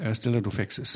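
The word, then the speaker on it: Stella Doufexis: Stella Dufexis, habe ich es richtig ausgesprochen? – Stella Dufexis. Dufexis. Ja. Wobei Dufexis ist eigentlich die richtig griechische Aussprache, wenn man da ist. Also aber nur in der Anrede.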